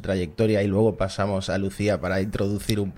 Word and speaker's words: LuzIA: ¿Cómo lo pronunciáis? Lucía, Lucía. Lucía. escribimos Luz IA, pero la llamamos Lucía.